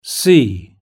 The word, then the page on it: sea: British English